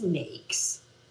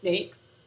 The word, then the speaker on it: NAICS: Well, basically, and it's NAICS. – Okay. – Kind of like N-A-K-E-S, NAICS.